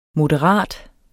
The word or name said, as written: moderat